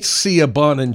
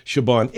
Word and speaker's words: Siobhan: It ain't Siobhan. It's an Irish name. It's a beautiful name, Siobhan.